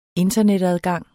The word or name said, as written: internetadgang